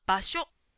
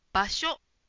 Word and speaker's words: 場所: 場所と覚えているかな？正しい発音は場所、場所です。うーはつけないように気をつけましょう。